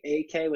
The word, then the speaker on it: Ekeh: You can find me on Twitter, Instagram, under Quality AK, which is my last name.